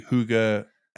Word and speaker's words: hygge: It's spelled H-Y-G-G-E. – Yeah. – So it could be hygge, however you pronounce it, but hygge.